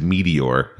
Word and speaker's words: Meteor: When you said Meteor. – The Meteor. – Meteor, yes. – Am I saying it wrong? – No. – The Meteor. – I heard Meteor, like more meat. – The Meat Eater.